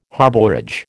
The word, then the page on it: harbourage: American English